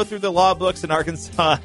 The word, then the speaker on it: Arkansas: Yeah, it looks like it's Arkansas, but in fact, it's Arkansas.